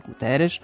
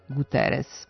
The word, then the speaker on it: Guterres: Tu S uvek ostaje S, pa Guterres u Portugaliji može da bude Guterres, ali kod nas on je Guterres.